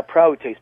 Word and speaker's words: prautes: But yeah, praotes, P-R-A-U-T-E-S, long E, the second one, that's exactly what it is, and it was used.